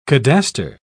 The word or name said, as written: cadastre